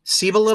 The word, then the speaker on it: Cibola: We've got book four, Chibola Burn. Uh, is it Chibola Burn? Cibola. Cibola Burn.